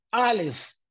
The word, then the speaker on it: alef: Y la letra Alef se pronuncia de la manera correcta, Alef, que viene desde adentro de la garganta.